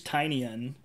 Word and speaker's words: Tynion: Right, well, it all came from James Tynion, and I think that's what we agreed how to say his last name, right? – Pretty sure that's what it is.